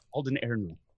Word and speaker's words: Alden Ehrenreich: Yes, Alden Ehrenreich.